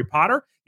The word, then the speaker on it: Potter: My name is Jerry Potter. Yes, it rhymes with the Boy Wizard,